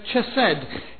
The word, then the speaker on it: chesed: The Hebrew word pronounced in an English way is chesed.